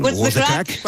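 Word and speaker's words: what's the craic: Con lo cual ahí podremos, decirte a ti y al resto de componentes de Cors, what's the crack? Creo que lo he dicho bien. – What's the crack? – Eso.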